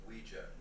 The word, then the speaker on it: Ouija: Ouija. – What? It's pronounced Ouija. – Oh. – It comes from the French and German words for yes. Oui and ya. Ouija.